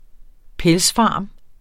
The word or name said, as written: pelsfarm